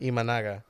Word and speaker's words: Imanaga: For the NL, we got Tyler Glasnow, Ryan Helsley, Jeff Hoffman, Shota Imagana? I know I butchered the Imanaga. – Hell out of that. Imanaga.